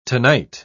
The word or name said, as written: tonight